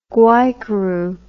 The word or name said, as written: guaycuru